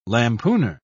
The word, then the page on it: lampooner: British English